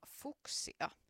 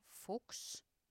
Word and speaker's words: Fuchs: Kirjoitetaan F-U-C-H-S, eli saksalaisittain se voisi lausua Fuchs, mutta sitten kun otetaan täältä America mukaan, niin lausutaan, tai täällä lukee, että pronounced Fuchs, niin ei tule sitten ainakaan vahingossa sanottu mitään kirosanaa. Mutta minä sanon sen nyt Fuchs, koska ollaan Euroopassa, niin Dana Fuchs on siis amerikkalainen laulaja, lauluntekijä.